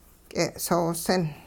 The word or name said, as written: k'e'soosin